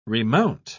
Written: remount

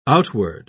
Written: outward

